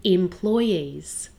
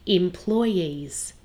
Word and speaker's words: employees: In the word employees, there are two ways to pronounce it. Some people put the stress on the second syllable, employees, and some people put the stress on the last syllable, employees. Both are correct.